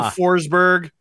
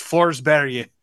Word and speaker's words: Forsberg: Peter Forsberg is not a person during the duration of this tournament. It's Forsberg. – Really? – That's how you say it.